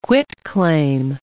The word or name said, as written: quitclaim